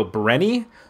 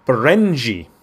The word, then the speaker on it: Berenyi: Her last name, it's Berenyi. Now you look at that, you go, Brenny? No, it's a hard J because it's Hungarian. – Okay. – Berenji. Mickey Berenji.